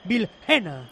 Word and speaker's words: Villena: Por tanto, se van en barba y Keydy Vare, y entran Melendo y Villena, o Villena, ¿cómo se pronuncia? – Villena.